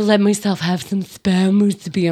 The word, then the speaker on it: musubi: I know that the word is poke, but, you know, they're not gonna have like spam musubi. Is that how you say it? – Musubi, yeah.